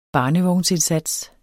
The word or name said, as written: barnevognsindsats